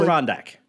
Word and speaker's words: Adirondack: Adirondack. Am I saying that correctly? – Adirondack. – Adirondack. The Adirondack Mountains. There we go.